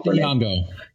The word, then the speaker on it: Lupita Nyong'o: But this really, we had some great, Lupita Nyong'o, I don't know how to say her name. – Lupita Nyong'o.